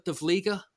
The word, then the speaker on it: De Vlieger: I have interviewed him and he taught me how to say his name and I've forgotten. Hurt de Vlieger? De Vlieger? – Yep.